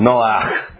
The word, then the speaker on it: Noach: In Hebrew, you say it like this, Noach.